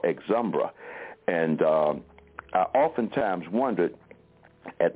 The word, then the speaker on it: Exumbra: But the magazine was called Exumbra, spelled E-X, one word part, and then U-M-B-R-A, E-X-U-M-B-R-A.